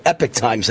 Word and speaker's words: Epoch Times: I'm sorry, Epic Times, how they pronounce it, but it's spelled Epoch Times. But it's Epic Times.